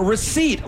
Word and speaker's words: receipt: All right, Juan. Receipt. Like the cashier gave you a receipt. – P.